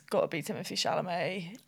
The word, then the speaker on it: Chalamet: Calumet. – Chalamet. – Okay. Fucking hell. Take two. Timothy Chalamet.